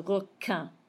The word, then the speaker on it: requin: The word for shark, requin, that Q-U, underline it. Q-U sounds like a K sound. You never pronounce the U when it's followed when it's following a Q. So requin.